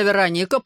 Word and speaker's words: Вероника: «Имя Вероника произносится с ударением на «о», — поправил меня Славик. «От города Верона».